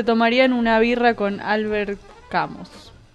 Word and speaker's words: Camus: Camus, sí.